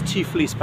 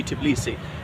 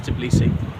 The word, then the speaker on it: Tbilisi: Known as Tiflis back then, but that's a mispronunciation. It should be Tbilisi. And now even in Russian, it is Tbilisi.